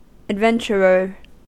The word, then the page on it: adventurer: American English